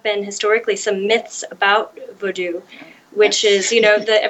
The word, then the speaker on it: Vodou: I would first say for people to forget everything they've ever heard about Vodou, V-O-O-D-O-O. If people notice I'm pronouncing it Vodou, V-O-D-O-U, which is the Haitian Creole spelling.